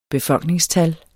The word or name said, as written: BEFOLKNINGSTALLENE